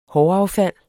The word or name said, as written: håraffald